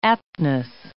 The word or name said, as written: aptness